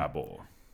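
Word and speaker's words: Daboll: Dabble.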